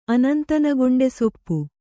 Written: ಅನಂತನಗೊಂಡೆಸೊಪ್ಪು

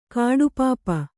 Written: ಕಡುಪಾಪಿ

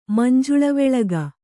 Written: ಮಂಜುಳವೆಳಗ

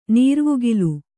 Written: ನೀರ್ವುಗಿಲು

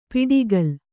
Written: ಪಿಡಿಗಲ್

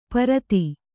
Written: ಪ್ರತಿ

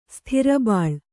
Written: ಸ್ಥಿರಬಾಳ್

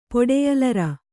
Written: ಪೊಡೆಯಲರ

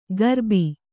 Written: ಗರ್ಬಿ

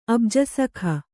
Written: ಅಬ್ಜಸಖ

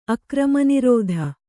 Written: ಅಕ್ರಮನಿರೋಧ

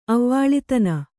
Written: ಅವ್ವಾಳಿತನ

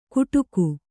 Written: ಗುಡುಗು

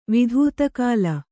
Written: ವಿಧೂತಕಾಲ

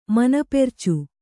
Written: ಮನಪೆರ್ಚು

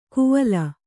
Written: ಕುವಲ

